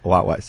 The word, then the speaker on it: Huawei: And then finally, it is Huawei, people. Okay. Not Huawei, who we are. None of that other nonsense. – Yeah. – Okay. I know Jay something did a thing. – The Huawei. – It's Huawei.